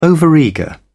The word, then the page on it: overeager: British English